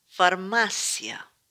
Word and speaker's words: Farmácia: Words with accents are stressed where the accent falls. Está. Farmácia.